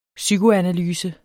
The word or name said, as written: psykoanalyse